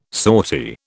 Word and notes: sortie: British English

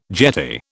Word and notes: jetty: British English

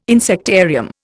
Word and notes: insectarium: Indian

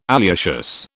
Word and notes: alliaceous: British English

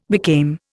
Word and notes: became: Indian

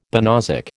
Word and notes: banausic: American English

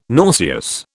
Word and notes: nauseous: British English

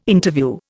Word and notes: Interview: Indian

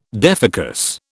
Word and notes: defocus: British English

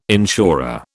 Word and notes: insurer: British English

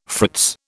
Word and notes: fritz: British English

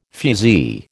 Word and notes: fusee: American English

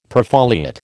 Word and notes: perfoliate: American English

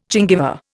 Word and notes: gingiva: Indian

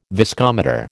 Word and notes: viscometer: American English

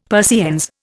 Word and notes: Persiennes: Indian

